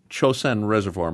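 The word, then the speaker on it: Chosin Reservoir: One of the first things that they had to deal with was the evacuation from the Chosun Reservoir.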